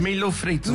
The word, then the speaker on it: Milho frito: Milho frites. – No. – Am I saying it right? – Close. Yeah, sure. – How do I say it? – Milho frito. – Milho frito.